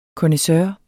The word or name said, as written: connaisseur